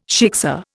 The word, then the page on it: shiksa: Indian